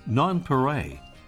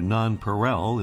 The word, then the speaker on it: nonpareil: France gave the bird another name by which it's often called, nonpareil, or nonpareil in the English pronunciation, meaning unrivaled, as a way to characterize its incredible coloration.